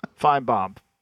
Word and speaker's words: Finebaum: Is that how you say his name, Finchbaum? – Finebaum.